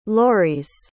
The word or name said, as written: louriz